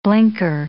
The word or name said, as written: blinker